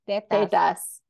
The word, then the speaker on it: tetas: Tetas. – You have to say it like the Spanish say it.